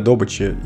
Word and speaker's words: добыча: Все привыкли слышать «добыча», но геологи говорят «добыча».